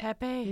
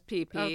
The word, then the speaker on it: Pepe: Pepe? And, Pepe. – No. She pronounces it as PP.